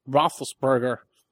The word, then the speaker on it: Roethlisberger: Now, on the other side, what if a Roethlisberger, did I say that right? – Yeah, the quarterback for the Steelers.